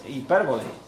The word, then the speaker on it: hipérbole: Bueno, también hay lo que se llama hipérbole, ¿verdad? ¿Se dice así? Hipérbole. ¿Qué es la hipérbole? Mentir con permiso. Hombre, yo uso la... es la, ¿no? Es hipérbole.